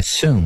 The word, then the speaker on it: Sean: O porta-voz do Pentágono, Sean Parnell, disse Sean, como é que fala? Sean, né? – É Sean, né?